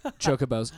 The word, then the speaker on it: Chocobo: I think it's chocobo. – Chocobo, sorry. – Chocobo. – I said it wrong.